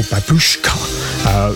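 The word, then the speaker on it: Babooshka: that was Kate Bush and Babushka, or Babushka. That's how it's supposed to be pronounced, but you know, us DJs, we like to do things differently.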